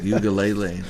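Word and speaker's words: Uiagalelei: Played a ukulele? It's like Ugalele. That's how you say his name.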